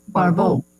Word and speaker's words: Barbault: We have some time here to go into what is being spoken of throughout the entire astrological community, what André Barbault, a French... – Barbault. – Yeah, thank you. My pronunciation, Barbault, brought to general attention.